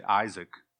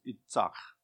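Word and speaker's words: Itzach (יצחק): You say Isaac in our American pronunciation, but it's Itzach.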